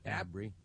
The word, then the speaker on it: Abri: And Ken, and then is it Abri? – Abri, am I saying that right? – Yes. – And how's that spelled? – A-B-R-I.